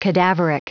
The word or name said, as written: cadaveric